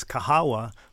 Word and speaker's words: Kahawa: Well, it's interesting because there's a story there that the Kahwa is the Arabic and Kahawa is the Swahili, and Kahawa is the word for coffee in Swahili.